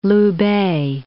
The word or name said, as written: Loubet